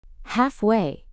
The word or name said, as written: halfway